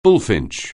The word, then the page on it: bullfinch: anglais